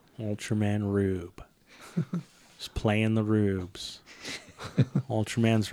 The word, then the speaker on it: R/B: The one we're watching right now, it's written as Ultraman R/B, but it's pronounced Rube. – Okay. – Rube.